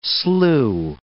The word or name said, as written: slew